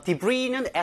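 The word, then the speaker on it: Debris: Debris는 S가 발음이 안 됩니다. 묵음인데요.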